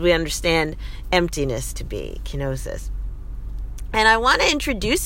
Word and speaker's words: kenosis: And one of these ancient Greek words is called kenosis, and that is spelled K E N O S I S, kenosis.